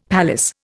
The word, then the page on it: palace: Indian